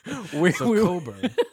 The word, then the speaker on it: Cockburn: Because another person I can't pronounce is it's spelt Cockburn. So we had Thaibut and Cockburn emailing us right now. – Right, well, no, we didn't, but we were pronouncing them that way.